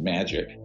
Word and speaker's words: MAJIC: So that's where I get into the Majority Intelligence Committee, MAJIC. It's pronounced magic, ironically, or not ironically.